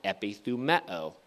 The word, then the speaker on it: epithumeo: I'll spell that for you. E-P-I-T-H-U-M-E-O, epithumeo.